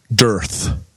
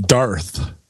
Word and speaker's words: dearth: But I've been kind of called out on the pronunciation of the word dearth, because I pronounce it Darth.